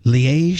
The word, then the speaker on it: Liège: L-I with a French gimmick over the E-G-E. Liège style waffles,